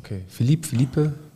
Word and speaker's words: Philippe: Aber ich glaube Philipp ausgesprochen. – Okay, Philippe.